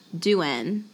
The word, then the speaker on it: Douen: So it is pronounced Duen. D-O-U-E-N. Duen.